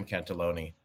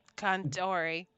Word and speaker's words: Cantore: Maybe we should ask Jim Cantaloni. – Cantori. – Okay. Cantors.